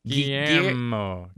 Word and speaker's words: Guillermo: Guillermo. – I was trying to say it earlier. – Guillermo.